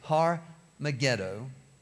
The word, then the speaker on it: Har Megiddo: Now, if we would say it in English, it would be Har, H-A-R. You don't always pronounce all the letters in other languages, but we'd say Har Megiddo, the hill of Megiddo. By the time it gets to the Greek, because the H is not pronounced, it's Armageddon. All right, so this is where the last battle supposed would take place.